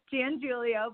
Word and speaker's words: Giangiulio: It's Giangilio, but you were close.